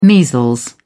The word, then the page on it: measles: British English